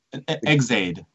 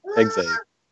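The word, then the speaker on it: Ex-Aid: Kamen Rider is it execute now? – Exade. – Exade.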